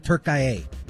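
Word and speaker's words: Türkiye: And they call it Turkiye? – Every commentator calls it Turkiye.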